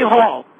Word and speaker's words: Hall: Hall. – Could you spell that? – Could I spell it? H-A-L-L.